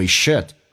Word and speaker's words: should: I think we should Think 的可 should 的 d 都不发音，略音。